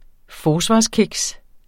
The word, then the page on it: forsvarskiks: [ -ˌkegs ]; [ -ˌkigs ]